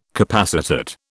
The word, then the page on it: capacitate: British English